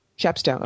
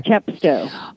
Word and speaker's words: Chepstow: Pronounce it for me. Chepstow, okay. – Chepstow. – I've long been... Yes, short E.